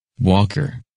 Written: Walker

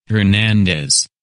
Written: Hernandez

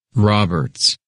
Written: Roberts

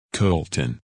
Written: Colton

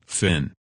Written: Finn

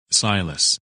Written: Silas

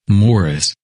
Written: Morris

